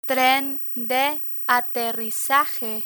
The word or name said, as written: tren de aterrizaje